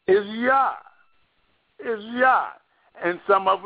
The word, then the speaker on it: Yah: But for sure, if we want to really know that we're pronouncing his name correctly, we can use his name right here in Psalm 68, verse 4, and it's Yah.